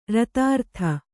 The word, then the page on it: ರತಾರ್ಥ: ratārta